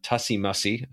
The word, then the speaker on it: Tussie Mussie: I want to switch gears a little bit, and I want to talk because I started researching one of your other games, Tussie Mussie, and I think that's how it's pronounced. Is that yes.